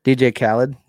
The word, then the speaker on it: DJ Khaled: It's my neighbor has been playing a lot of DJ Khaled. Is that how do you say his name? – DJ Khaled.